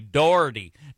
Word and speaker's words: Doherty: He said it rhymes with authority, Doherty, Dr. Doherty.